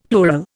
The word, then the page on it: plural: Indian